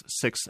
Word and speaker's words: Sikh: It is Sikh. And it's Sikh with a harder sounding H at the end of it. It's a K and an H. It's S-I-K-H. And I think if you look at the Gurmukhi, which is our language, it is spelt the way it would sound to be Sikh.